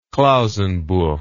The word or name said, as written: klausenburg